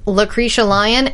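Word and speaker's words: Lacretia Lyon: Well, hey guys, I am Lucretia Lyon,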